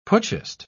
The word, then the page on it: putschist: British English